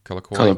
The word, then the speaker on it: Colloquy: Colloquy.